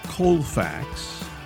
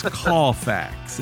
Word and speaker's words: Cawfax: It's not Colfax. It's Cawfax. It's spelled C-A-W-F-A-X. Cawfax. And it's good to know. – Yes. If you say Colfax, they'll know you're from out of town.